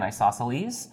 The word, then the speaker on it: isosceles: So isosceles triangle, there's no, you don't pronounce the C there in isosceles.